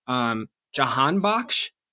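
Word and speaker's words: Jahanbakhsh: But lastly, I just wanted to touch on Jahan Bakhsh.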